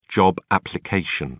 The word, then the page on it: job application: British English